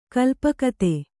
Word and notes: ಕಲ್ಪಕತೆ: kalpakati

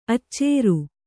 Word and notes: ಅಚ್ಚೇರು: Kannada

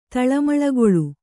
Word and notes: ತಳಮಳಗೊಳ್: Kannada

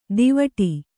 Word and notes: ದೀವಟಿ: dīvaṭi

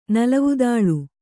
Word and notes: ನಲವುದಾಳು: Kannada

